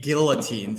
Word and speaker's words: guillotine: I have an idea that I'm probably going to get guillotined because it's not a silent L apparently when you're in New York, guillotined for.